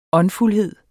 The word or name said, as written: åndfuldhed